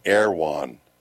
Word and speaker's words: Erewhon: It's spelled Erewhon. So we were trying to figure out, what the heck is that? They pronounce it Erewhon.